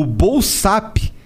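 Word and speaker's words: Bolsapp: O Bolsap, que se escreve assim, ó, B O L S A P P, é bolsa com dois P's no final.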